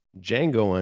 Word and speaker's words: Django: Django. The D is silent. Django Unchained 2012,